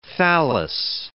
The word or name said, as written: thallus